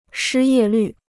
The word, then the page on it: 失业率: shī yè lǜ